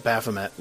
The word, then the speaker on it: Baphomet: Yeah, they're going to expect you to be all up on Baphomet, which is the way to pronounce that, by the way. I kept pronouncing it Baphomet, which sounded terrible. Then I heard Rob is like, no, that sounds much more dignified. I was saying it like vomit.